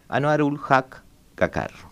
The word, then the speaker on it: Anwarul Haq Kakar: En definitiva, hoy está en el poder Anwarul Haq Kakar.